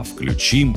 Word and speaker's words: включим: Во всех личных формах глагола «включить» ударение падает на последний слог. И этот вариант единственно верный. Никаких «включим» и «подключим» нормы русского литературного языка не допускают.